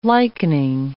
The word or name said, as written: likening